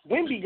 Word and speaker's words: Wimby: We are still here with no, also known as is that Miss Wimbley? Am I saying that correctly? – Wimby girl, no L, no L. Wimby girl, Wimby girl.